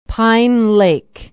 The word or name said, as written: pine lake